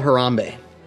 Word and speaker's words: Harambe: Yeah, I love the Tam Tam drummers of Harambe.